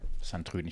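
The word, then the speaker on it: Sint-Truiden: St. Trüden. – Genau, ja. So. Korrekt. Santrüden.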